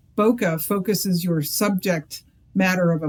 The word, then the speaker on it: Bokeh: What is Bokeh, or Bokeh, I should say? – Sure. Well, we pronounce it Bokeh, and it has to do with the photography term about fuzzy backgrounds.